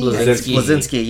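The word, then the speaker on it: Bleszinski: Cliff Blazinski? – Blazinski, yes.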